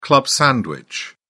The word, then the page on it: club sandwich: British English